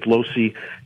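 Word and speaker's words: Lohse: to Bishop Lose. Is that how you say his name? What happened with him? – Losey. Bishop Edward Losey.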